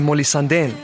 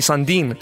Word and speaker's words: Sandén: Vi ska från Summertime, Etty, till slut av sommaren med Molly Sandén. Eller som min vän Hassan säger, Molly Sandin.